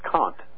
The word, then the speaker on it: Comte: C-O-M-T-E, which is pronounced Kant, like Count.